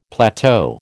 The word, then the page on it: plateau: American English